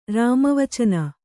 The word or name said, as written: ರಾಮವಚನ